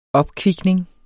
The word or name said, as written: opkvikning